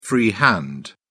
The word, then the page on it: free hand: British English